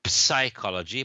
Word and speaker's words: psychology: We write it psychology, but the P is silent. Okay, so it's psychology.